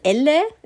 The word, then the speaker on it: Elle: Fokusbunte natürlich L, Elle, e, L, L, spricht man Ach, L aus, oder? – Ja.